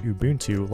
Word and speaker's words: Ubuntu: It's not Ubuntu like a sandwich, but Ubuntu like the Zulu word for kite.